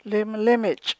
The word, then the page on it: limlimich': chukchansi